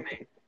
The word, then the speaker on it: Aljamain: There you go, Aljamain.